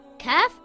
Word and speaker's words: Kaf: Kaf is a light letter and should not be pronounced heavy. Kaf makes a similar sound in English to the letter K in the word kitten or the word kangaroo.